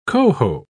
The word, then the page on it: cohoe: British English